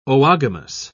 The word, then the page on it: oogamous: British English